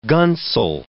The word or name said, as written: gunsel